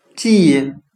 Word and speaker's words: gene: 我们用谐音法来记忆这个单词 gene，它的发音很像汉语的基因，基础的基，因为的因。